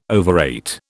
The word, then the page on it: overate: British English